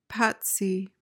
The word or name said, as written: patsy